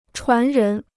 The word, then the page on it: 传人: chuán rén